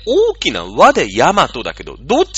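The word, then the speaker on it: 大和: あれは、もう大きな和と書いて、ヤマトって読むしかないんですよ。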